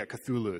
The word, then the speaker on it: Cthulhu: I mean, there's the, I'm going to mispronounce it, but there's Cthulhu, how do you pronounce it? – Cthulhu? – Yeah, Cthulhu.